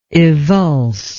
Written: evulse

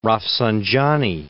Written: Rafsanjani

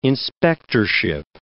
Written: inspectorship

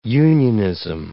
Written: unionism

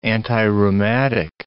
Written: antirheumatic

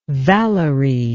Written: Valerie

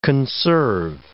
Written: conserve

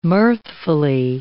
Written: mirthfully

